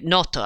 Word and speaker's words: Noto: Noto actually is how it's pronounced, that the two girls go to and spend the night there.